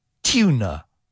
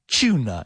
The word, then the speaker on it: tuna: However, listen to that, because atún in English is normally pronounced tuna or tuna, even like a CH sound, tuna or tuna.